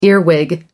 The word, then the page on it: earwig: American English